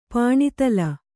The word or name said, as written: ಪಾಣಿತಲ